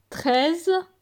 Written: 13

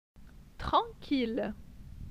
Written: Tranquille

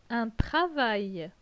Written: Un travail

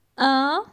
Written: 1

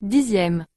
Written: 10e

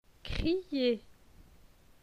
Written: Crier

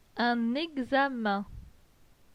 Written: Un examen

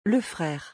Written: Le frere

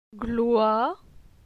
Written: Gloi

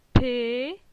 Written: Pe

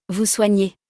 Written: vous soignez

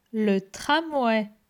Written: Le tram

